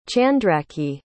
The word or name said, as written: chandraki